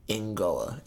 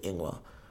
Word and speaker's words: ingoa: And one of the kids, I'll never forget, Josh, laughed at me when I said Ingoa instead of Ingwa.